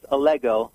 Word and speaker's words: Allego: it's it's pronounced Allego, uh, rhymes with Lego my ego.